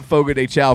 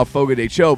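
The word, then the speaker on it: Fogo de Chão: And Fogo de Chao came up and said, it's not Fogo de Chao, Fogo de Cho.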